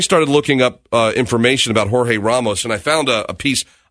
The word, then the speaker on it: Jorge: And Univision anchor George, or is it George or Jorge? How did you pronounce his name? Jorge Ramos? – I think so. – It's Jorge Ramos.